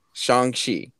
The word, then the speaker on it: Shang-Chi: I think it's Shang-Chi. Shang-Chi. That's how you pronounce it.